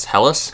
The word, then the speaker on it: Helles: It's H-E-L-L-E-S. Helles? – Helles.